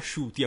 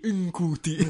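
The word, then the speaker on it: Ncuti: questa Shuti si legge si pronuncia Shuti a quanto pare ah. l'ho scoperto recentemente è più facile è più facile di Nkuti Nkuti Nkuti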